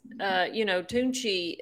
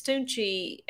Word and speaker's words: Toonche: It has more to do with, Tunchi. It's spelled a different way for Little Wayne, but it's Tunchi after Little Wayne.